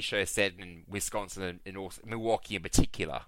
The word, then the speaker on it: Milwaukee: So actually, it's pronounced Milwaukee.